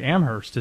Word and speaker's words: Amherst: Now, I mean, apparently Amherst is how you say it.